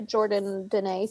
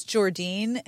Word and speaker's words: Jordan Denae: I can attest to Jordan Denae stuff. I own a lot of stuff. – All this time, I thought it was Jordine, and I have been wrong. – There's an extra syllable in there.